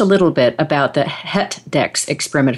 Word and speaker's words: HETDEX: So, yes, you pronounce it perfectly. It's indeed called HETDEX.